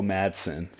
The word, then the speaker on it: Madsen: Michael, I believe Medson is how it's pronounced. I, gotta look it up now because it's gonna bother yeah. me. But uh, from Reservoir Dogs, Michael Myers, Michael Madsen from Reservoir Dogs.